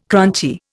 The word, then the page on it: crunchy: Indian